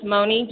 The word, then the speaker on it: Moni: Yes, it's Moni, just M O N I, Law, L A W.